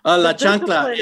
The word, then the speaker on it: chancla: What is La Chancla? Did I say that right? – La Chancla, it is the slipper.